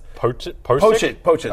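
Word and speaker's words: Pocic: How do I pronounce his? – Poachit. – No, I was going to say Dolchett. That's the tight end in Denver. Sorry. Poachit, right.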